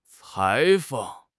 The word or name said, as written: 裁缝